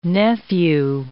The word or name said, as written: nephew